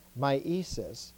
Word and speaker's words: myesis: The first is miesis, or initiation, M-Y-E-S-I-S, with the accent on the Y.